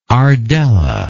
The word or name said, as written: ardella